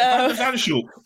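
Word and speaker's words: van de Zandschulp: Bertik van der Zandschulp. – I'm going to call him Van. Van. Okay. So Van der Zandschulp.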